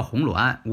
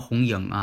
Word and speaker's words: 红鸾: 这个呢叫红鸾，无论是四柱当中，还有这个紫微斗数当中都有这个名词红鸾，不要把它念成红英啊，怎么念成红英呢？红鸾嘛。